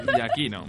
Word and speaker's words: Giacchino: Pues bueno, comentar también eh, Michael Giacchino, es, o Giacchino, no, sé muy bien cómo se pronuncia, compositor. – Giaquino mismo.